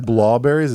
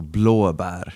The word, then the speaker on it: blåbär: So is it blawberries? Is that how you say it? Blawberries. – So blue in Swedish is blåa. – Blåa. – So basically like blow. Mm-hmm. Blåa. And then berry in Swedish is bär. So blåbär.